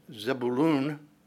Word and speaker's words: Zebulun: When we come to Zebulun, probably more accurately pronounced Zebulun,